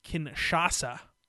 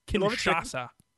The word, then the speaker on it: Kinshasa: What do you think of the change from the Bamae to the Kinshasa? – It's going to be hard for me to pronounce. Right? – It is a bit tricky. Kinshasa. – Kinshasa.